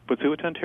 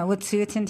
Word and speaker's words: Wet'suwet'en: I don't know how to pronounce it again. What is it? – The Wet'suwet'en territory? – Yeah, Wet'suwet'en territory.